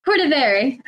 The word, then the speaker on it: Cordivari: Cordivari.